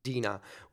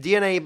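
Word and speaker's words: DeNA: So there was this joint conference with Satoru Iwata from Nintendo, and I don't remember the name of the CEO of Dina, which I think it's supposed to be pronounced DNA, but we just say Dina. I get it wrong every single time.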